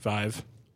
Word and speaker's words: Vive: The Vive. – According to Addy, it's pronounced Vive like Hive.